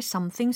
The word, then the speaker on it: something: 예, something sweet 이게 아니라 something sweet. 라고 아주 스윗하게 발음을 해주셨으면 좋겠어요. 많은 분들이 어, 제 주변에 어떤 가족분들도 덤띵이라고 잘못 발음하시는 분이 계셔가지고 코멘트 해드립니다.